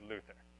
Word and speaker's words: Luther: All right, so let's start at the beginning with Luther. Pronounce Luther, because there's no TH sound in German. You wouldn't say th.